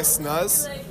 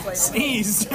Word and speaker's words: sneeze: Honestly, if I grew an inch every time I snuzz. Is that the correct pronunciation? – Sneeze.